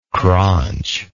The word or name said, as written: craunchingly